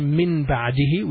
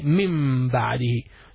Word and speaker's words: من بعده: So we don't say min ba'dihi, we say mim ba'dihi.